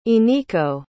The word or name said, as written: iniko